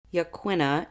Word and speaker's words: Yaquina: Yaquina, if you don't know, is spelled Y-A-Q-U-I-N-A.